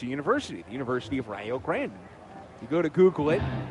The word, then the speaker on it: Rio Grande: In Rio Grande, Ohio, they pronounce it Rio Grande. Check over to first. And Ogans is back in time. I learned it the hard way of how to pronounce Rio Grande.